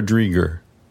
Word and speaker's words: Dreger: Is it Dreger or Dreger? – Rhymes with kegger.